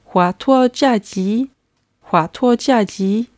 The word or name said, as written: huatuojiaji